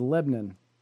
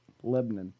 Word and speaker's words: Lebanon: The city that it's being held in is Lebanon, which is the correct way to pronounce that. Lebanon.